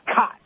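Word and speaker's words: Kaatz: And a shout out to Drew Kotz's mom, who sent a very lovely email and listening all the way in Phoenix, Arizona, making sure that we have the correct pronunciation spelled K-A-A, excuse me, K-A-A, yep, got that right, T-Z, but it is cot, almost like a military bed, a cot, but it's cots.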